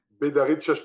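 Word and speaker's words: Bédarrides: c'est Bedarid, si c'est bien ça. – Bedarid, alors il ne faut pas oublier, c'est une entente, c'est Bedarid Châteauneuf-du-Pape.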